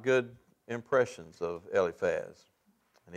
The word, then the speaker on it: Eliphaz: And I pronounce that Eliphaz, I think that's how John pronounces it. I have seen pronunciations where it's Eliphaz, but it seems like for the most part, it's the spelling pronunciations that you see and hear are Eliphaz, so I'll go with that.